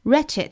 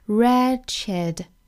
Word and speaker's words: wretched: 好，首先我们来看语音方面 For the wretched Wretched 这个单词，我们注意到这个 W 它的第一个字母是不发音的，直接读后面的 R 就可以了。 Wretched